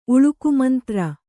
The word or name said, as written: ಉಳುಕುಮಂತ್ರ